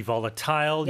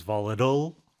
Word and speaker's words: volatile: So as an adjective, it could be volatile. You can pronounce it as volatile, right? More Americans would do that.